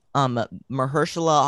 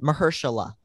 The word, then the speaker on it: Mahershala: But anyways, we hear Mahershala Ali, I think is how you pronounce it, as Blade in the background. Mahershala, not Mahershal.